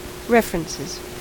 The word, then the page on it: references: American English